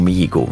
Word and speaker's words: Mihigo: De Rwandese muzikant Kizito Mihugo. de vraag is natuurlijk, hoe is hij aan zijn einde gekomen? Goedemiddag. Goedemiddag. Onze Afrika-kenner, zeg ik het goed, Kizito Mihigo? Ja, Kizito Mihigo, ja.